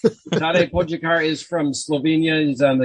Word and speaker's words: Tadej Pogačar: But so Tadej Pogačar. How'd I do? – You did pretty good.